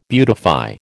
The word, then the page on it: beautify: American English